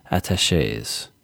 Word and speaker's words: attachés: So in 2017, I founded a student organization called the Cultural Attachés. Am I saying that right? – Yeah.